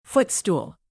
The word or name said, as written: footstool